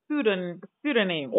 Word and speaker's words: pseudonym: It's called a pseudonym. Oh, the P is silent, I think, yeah.